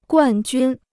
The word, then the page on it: 冠军: guàn jūn